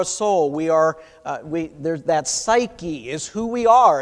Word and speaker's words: psyche: In the Greek, it's the word psyche, another silent P at the beginning.